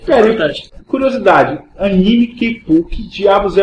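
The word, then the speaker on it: kepu (ケープ): Em japonês é kepu.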